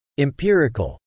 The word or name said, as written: empirical